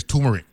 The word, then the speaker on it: turmeric: It's turmeric. You know what I'm saying? So because when you said it, I was looking for it. And I said, wait a minute. The way they spell it, they got the first R in it. I ain't hearing the R.